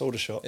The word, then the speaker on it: Aldershot: But no, it's Aldershot. – It's Aldershot. – Not everyone pronounces it Aldershot. – Oh, I can see, yes.